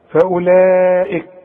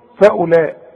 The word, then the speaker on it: فأولئك: فأولئك فأولئك كلها مرققة، لكن خد بالك من الواو اللي عليها الخمسة اللي العلماء بيسموه حرف علة ما تنطقوش. شوف فأولئك، شوف الغي من النطق الواو، تكتب ولا تقرأ.